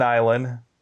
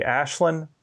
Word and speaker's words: Aislyn: Now, to be fair, the Irish pronunciation of the way her name is spelled would be Ashlyn, but that's not how she pronounces it. It's Island.